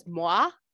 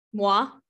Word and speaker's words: moi: Alyssa does write that Miss Piggy even has said, quote, starting today, Moy is a feminist while accepting an award. – I think she says moi. – Moi. Is that how you say it? M-O-I. – Because it's French.